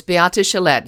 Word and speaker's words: Beate Chelette: Did I pronounce it correctly? – You you almost had it. It was Beate Chelette.